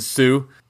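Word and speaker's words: Hsu: And then, I mean, the Stephanie, again, how do you say her name? Shu? It's H-S-U. I don't know how to pronounce that either. – Sue. – Is it Sue? – I think it's Sue.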